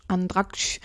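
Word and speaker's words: Andratx: In Andraksch. Habe ich es richtig ausgesprochen? Andraksch, genau.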